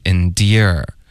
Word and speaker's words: endear: Endure 再加儿化音，后面 endear 是一个爹加儿化音。